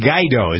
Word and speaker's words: Guaido: Our president moved to officially recognize Juan Guaido. Is that how you pronounce his name? G-U-A-I-D-O? Mm-hmm. Guaido? – Sounds right.